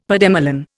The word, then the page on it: pademelon: Indian